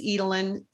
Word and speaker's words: Edelen: Um, you'll find me at Candyce Edelen, spelled with a Y, C A N D Y C E E D E L E N is my LinkedIn profile.